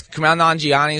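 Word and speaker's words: Kumail Nanjiani: And it's also Kamal Nanji is going to be there as well, right? Oh, I know I always say his name wrong. – Some hilarious. Kamal Nanjiani is on the show.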